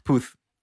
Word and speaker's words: Puth: Puth.